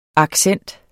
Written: accent

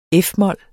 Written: f-mol

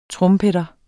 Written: trompeter

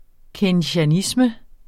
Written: keynesianisme

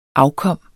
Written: afkom

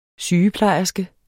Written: sygeplejerske

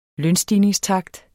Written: lønstigningstakt

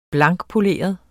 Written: blankpoleret

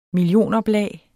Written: millionoplag

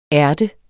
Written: ært

